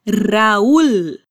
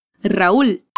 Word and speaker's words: Raúl: La R es vibrando la punta de tu lengua y la L es con la punta de tu lengua detrás de tus dientes superiores. Raúl. Raúl.